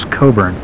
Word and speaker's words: Cockburn: I see Robert showing me a tape by Alexander Coburn, and that Coburn is spelled C-O-C-K-B-U-R-N, Cockburn, but I believe that the pronunciation is Coburn.